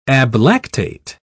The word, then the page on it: ablactate: British English